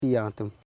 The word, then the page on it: jantum: jan.tum; Koro